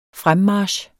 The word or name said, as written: fremmarch